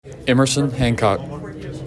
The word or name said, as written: Emerson Hancock